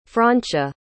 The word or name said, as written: francia